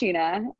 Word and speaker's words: Shenna: So my name looks like Shenna, but it's pronounced Sheena.